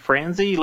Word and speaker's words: Franzi: If you'd like to connect with me, I'm Richard Franzi.